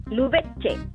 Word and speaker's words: Lubense: Y mi otra duda también que tengo, Sandra, ¿tu apellido se pronuncia Lubense o Lubense? – Lubense, es italiano.